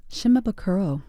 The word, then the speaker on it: Shimabukuro: Jake Shimaba. How do you say his name? – Shimabukuro.